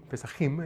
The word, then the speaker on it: Pesajim: Pero dice que hay un libro que se llama el Pesajim, Pesajim es la forma que se pronunciaba.